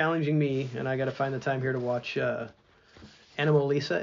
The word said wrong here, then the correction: Anomalisa: Anomalisa, I think it's like anomaly and then the name okay. Lisa. Anomalisa.